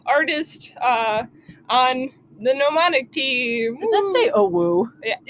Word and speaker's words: Gnomonic: We've got a little teamwork on it, but the game I'm working on is called Gnomonic, and it's a silent G N O M O N I C, and uh, if you like symmetry, well, you're gonna hate our logo because I'm the one who made it not symmetrical.